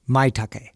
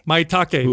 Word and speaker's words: Maitake: First off, we mentioned four sigmatics mushroom matcha latte with, how do you say it? – Maitake. – Maitake.